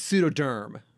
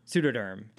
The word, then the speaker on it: Pseudoderm: I think it's pseudoderm. – Pseudoderm. – Like pseudo as in fake and derm as in skin? – Yes.